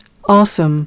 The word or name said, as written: Awesome